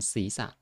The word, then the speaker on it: ศีรษะ: ศีรษะอันนี้เหมือนกันสั่นศีรษะรอเรือไม่ออกเสียง